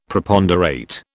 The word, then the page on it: Preponderate: British English